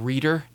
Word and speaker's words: Rita: So Milo loves to joke that one of Grandma Terry's best friends is Rita Weinstein, but she pronounces the name Reeter. Like Reeter, like you're saying, like Reeter Rabbit.